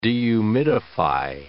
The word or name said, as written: dehumidify